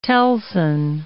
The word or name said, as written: telson